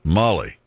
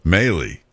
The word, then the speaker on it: Mahle: Molly? – I don't know. – Maley. M-A-H-L-E.